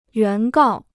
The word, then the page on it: 原告: yuán gào